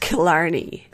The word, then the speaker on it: Killarney: I mean, could you imagine, like, leaning forward on an airplane and being like, actually, it's pronounced Killarney.